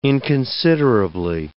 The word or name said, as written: inconsiderably